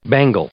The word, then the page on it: bangle: noun